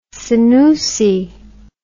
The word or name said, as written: senoussi